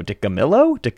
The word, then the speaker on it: DiCamillo: DiCamillo.